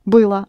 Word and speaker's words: было: Only don't pronounce O in the ending. – Было. – Было.